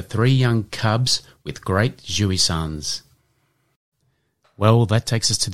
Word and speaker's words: jouissance: And this week's Word of the Week is jouissance. And that is spelt J-O-U-I-S-S-A-N-C-E.